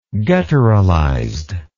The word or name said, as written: gutturalized